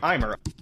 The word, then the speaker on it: Eimer: Elmer's organs were first described in the European mole in 1871 by German zoologist Theodor Eimer. I've been mispronouncing it this whole time.